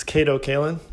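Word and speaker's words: Kato Kaelin: We have Kate Callan. Is that how you say your name? – It's Kato Callan.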